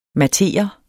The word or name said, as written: mattere